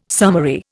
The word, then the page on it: summary: Indian